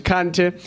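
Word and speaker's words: Kanté: In Western Africa, the Xhosa ruler, Samanguru Kant or Kante, I'm pronouncing that in my language, Kante, has been raiding and conquering people.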